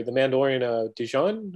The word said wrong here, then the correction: Din Djarin: How do you pronounce that name? Pronounce it like Jin. – Jin, yeah. – Din Djarin.